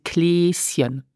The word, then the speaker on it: Kleeschen: And St. Nicholas is the Kläschen, and pronounce it Kläschen.